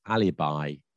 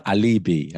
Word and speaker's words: alibi: I think this one most people know, but the pronunciation is sometimes confusing, and that is alibi, not alibi, as I've heard it being said.